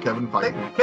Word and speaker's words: Feige: Kevin Feige.